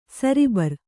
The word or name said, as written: ಸರಬರ